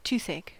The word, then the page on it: toothache: American English